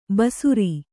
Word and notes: ಭಾಸುರ: Kannada

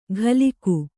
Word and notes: ಘಲಿಕು: ghaliku

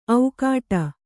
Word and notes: ಔಕಾಟ: aukāṭa

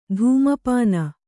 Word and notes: ಧೂಮಪಾನ: kannada

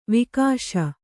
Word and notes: ವಾಗೀಶ: Kannada

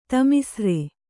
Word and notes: ತಾಮಿಸ್ರ: Kannada

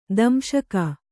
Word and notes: ದಂಶಕ: kannada; damśaka

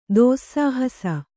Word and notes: ದೋಸ್ಸಾಹಸ: dōssāhasa

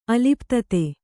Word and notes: ಅಲಿಪ್ತತೆ: aliptate

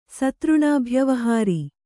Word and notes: ಸತೃಣಾಭ್ಯವಹಾರಿ: kannada

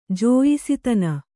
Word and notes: ಜಾಯಾಸ್ಥಾನ: jāyā sthāna

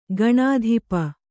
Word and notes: ಕಣ್ತಪ್ಪು: Kannada

